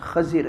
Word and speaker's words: خضر: خضر، صحیح تلفظ خضر ہے.